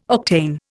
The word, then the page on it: octane: Indian